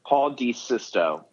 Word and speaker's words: Paul Desisto: Paul Desisto.